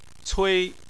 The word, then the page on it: cui: 1